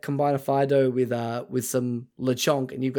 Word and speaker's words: Fidough: but also we got introduced to the best thing, which is Fido, which is spelled F-I-D-O-U-G-H, which is bread roll dog, and it's the best.